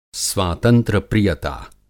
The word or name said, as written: स्वातंत्र्य-प्रियता